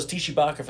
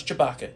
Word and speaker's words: Tshibaka: I thought it was Tshibaka for the longest time, but now it's Chewbacca.